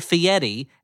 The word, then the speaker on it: Fieri: And Sally Field enters Guy Fieri, as they insist on pronouncing his name, because I guess that's how he pronounces it.